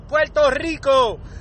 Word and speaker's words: Puerto Rico: We never kill the R on Puerto Rico. You never hear a Puerto Rican, even the ones that go, we don't go Puerto Rico. We all say it right, Puerto Rico, all right?